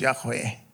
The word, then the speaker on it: Yahweh: Yeah, they didn't have vowels in ancient Hebrew or pointings, just consonants. We think it was probably pronounced Yahweh.